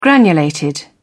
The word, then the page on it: granulated: British English